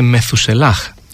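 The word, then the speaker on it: Μεθουσελάχ (מתושלח): Ο γιος του Ενόχ είναι ο Μαθουσάλα. Στα εβραϊκά προφέρεται Μεθουσελάχ.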